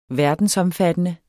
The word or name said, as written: verdensomfattende